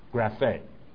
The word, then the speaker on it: graphe: So I looked up the word for scripture, and it's graphe, G-R-A-P-H-E, pronounced graphe.